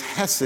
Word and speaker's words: chesed: The word is actually chesed. It's a word that you almost have to sound like you're clearing your throat to say it correctly. And, but it's hesed, it's a love that's enduring, steadfast, loyal, favorful, extends favor, it's responsive, it's action, it's participatory.